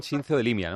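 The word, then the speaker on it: Xinzo de Limia: a ver si lo digo bien, Sincio de Limia, ¿no? – Sí, sí, de ahí mismo.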